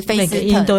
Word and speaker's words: First: 然后我这一次走了几条，大概那个少女峰那一边，嗯、就是像呃有一个地方叫做 First，写起来就是我们的 First。嗯，对，可是那个他他们的发音的话，就是菲斯特这样子，对对对。